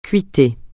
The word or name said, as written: cuiter